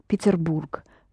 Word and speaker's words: Петербург: Petersburg. There's no Z. Петербург. So, I'm soon traveling to Petersburg.